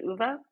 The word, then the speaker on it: OOVA: We do pronounce it OOVA, but there is the intent of having the word OOVA in there, obviously.